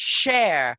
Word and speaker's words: Schär: But it's pronounced Cher.